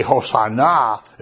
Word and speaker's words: Hosanna: And if you say Hosanna, it means come and save us now. You know, the accent on the last syllable of this word makes it emphatic.